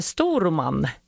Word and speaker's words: Storuman: Nu har vi med oss från Storuman. Hej! – Ja, hej! – Hej, nu sa jag kanske, uttalade jag ortsnamnet kanske lite fel. Hur uttalar man det? – Storuman. – Storuman, precis.